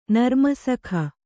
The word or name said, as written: ನರ್ಮಸಖ